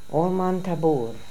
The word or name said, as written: Olmantaboor